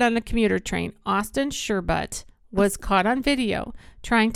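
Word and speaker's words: Shurbutt: It's spelled Sherbutt. – S-H-U-R. Sure. – Sure. – But. – But. – B-U-T-T.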